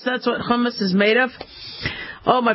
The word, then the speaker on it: hummus: It's how you pronounce it, by the way. It's not hummus. It's hummus.